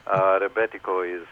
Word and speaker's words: Rebetiko: Rebetiko is a good one, and that even if you say it in Greece, people would know exactly what you're talking about.